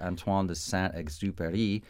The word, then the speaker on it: Antoine de Saint-Exupéry: Antoine de Saint-Exupéry, he was an aviator himself.